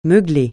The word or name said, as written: meugler